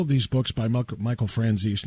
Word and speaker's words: Franzese: You probably have heard the name of Michael Franzese, or Franzese. You may have heard it pronounced that way as well. I've always heard it and pronounced it Franzese.